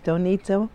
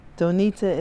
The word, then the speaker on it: Donita: It's Iris Donita Bowen, and it's spelled I-R-I-S. Donita is D-O-N-I-T-A, and my last name is Bowen, B-O-W-E-N.